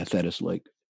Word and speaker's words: Thetis: Is it Thetis Lake? – Yeah, Thetis Lake.